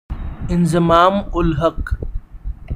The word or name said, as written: Inzamam-ul-Haq